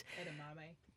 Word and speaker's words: edamame: like endnami. Is that how you pronounce it, beans? – Edamame.